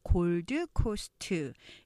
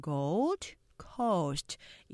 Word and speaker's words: Gold Coast: 자 첫 번째 단어 보면 우리가 보통 골드 코스트 이렇게 지역을 이렇게 다섯 음절로 알고 계신 보이시는데 실제적으로 음절 보시면 이 gold coast 이렇게 두 개로 발음되죠. 우리가 첫 번째 수업하셨을 때 우리 그 배웠죠. 시드니 삼음절이 아니라 시니 이음절 하는 것처럼 이것도 gold coast 이음절로 하면서 t 발음 거의 안 나요.